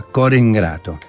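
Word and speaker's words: Core 'ngrato: En realidad es Cor Ingrato, la I no se pronuncia, Cor Ingrato, en napolitano.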